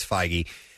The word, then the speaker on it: Feige: We've pronounced it Feige and Fague and Feige, and I think it's Feige.